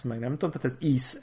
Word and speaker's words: Ys: De most furcsa, furcsa módon azt úgy kell kiejteni, hogy íz, Tehát egy YS.